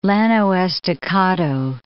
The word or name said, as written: Llano Estacado